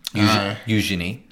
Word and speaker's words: Eugenie: Could have been Eugenie. Eugenie. That's how you say it, sorry.